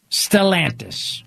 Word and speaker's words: Stellantis: Right now, there's an automaker called Stellantis. Stellantis. Rhymes with DeSantis, but no relation.